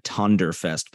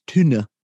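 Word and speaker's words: Tønder: It looks like Tonderfest, but apparently it's pronounced Tuna.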